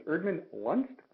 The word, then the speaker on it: Erdman-Luntz: I have Laura, and her last name is Erdman-Luntz. Did I say that correctly? – You did.